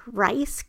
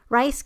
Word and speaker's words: Reiss: For extrasensory perception, the two most extreme published examples of ESP in scientific literature have been those of the, I don't know how to say this name, Rice case. It's spelled R-E-I-S-S, Rice case of a 26-year-old female at Hunter College, New York State in 1936, and of Pavel Stepanek?